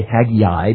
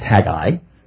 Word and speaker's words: Haggai: Two syllables, not three, by the way. I know when you're learning the Old Testament books of the Bible, it's easy to say Haggai because it rhymes with Zechariah and Malachi, but it's really just Haggai.